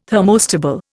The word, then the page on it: thermostable: Indian